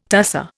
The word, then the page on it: tusser: Indian